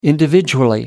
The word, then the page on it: individually: American English